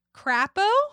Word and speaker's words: Crapo: Senator Mike Crapo. Okay. You tell me how you would pronounce this name. C-R-A-P-O. – That's what it is. – Crapo.